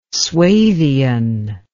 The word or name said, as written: suevian